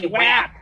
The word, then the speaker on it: WAP: It is WAP. Like you're supposed to say WAP.